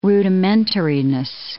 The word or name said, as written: rudimentariness